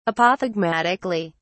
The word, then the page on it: apothegmatically: British English